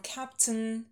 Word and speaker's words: captain: Капитан, captain, captain. Where is the captain? Первый у нас ударный лягушка.